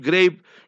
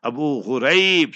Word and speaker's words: Abu Ghraib: called Abu Ghraib, not Abu Ghraib, Grape. You must never pronounce how these enemies of Islam pronounce Gaza, is Gaza. Abu Ghraib, Grape. No grape, grape in grapes business. Abu Ghraib.